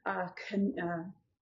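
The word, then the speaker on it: Chanok (חנוך): Enoch is actually, if you're looking at it, is Chanok. Chanok. If you say it right. Get the guttural in there. Chanok. Uh-huh. Would be Enoch.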